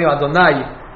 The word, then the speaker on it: Adonai: Or according to Sfaradi, Adonai, to emphasize the yud at the end. The ay, which the word brings it down. Or the oy, you say oy, ay, whatever you say. But pronouncing it milera. Milera means emphasis at the end of the word.